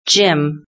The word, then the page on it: gym: British English